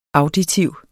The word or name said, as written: auditiv